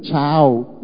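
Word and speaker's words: ciao: Bảo không phải đâu mày ơi, tiếng Ý là chữ C, Y ngắn A O, nó phát âm giống như là chào, cũng có nghĩa là hai hay là chào. Nó giống, phát âm giống tiếng Việt, chứ nó không phải là nó nói tiếng Việt như mày đâu mà mày tưởng nó nói tiếng Việt của mày.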